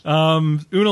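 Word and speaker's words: Oona: Una? Is that how you say it? Una? – I think so. – That's the only way to say it, really.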